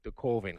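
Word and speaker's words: Coven: Oh, that but the guy that made the Colvin, because he doesn't want to rhyme with oven, is just, he's completely sincere, and he makes just this god awful movie.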